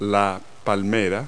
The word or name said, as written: palm tree